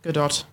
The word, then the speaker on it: Gadot: Is that how you say her name? Gadot? Yeah. Gadot? – Gadot.